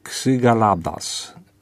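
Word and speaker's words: Xygalatas: a autorem jest Grek Dimitris Ksygaladas. Ksy to się pisze się przez X.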